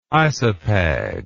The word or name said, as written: isopag